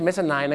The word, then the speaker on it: mezzanine: mezzanine ไม่ใช่ mezzanine นะครับ mezzanine เหตุผลที่เขาต้องออกเสียงว่า nine เนี่ยเพราะถ้าไปบอก mezzanine เดี๋ยวมันนึกว่าเป็นชั้น 9 เดี๋ยวจะทับกัน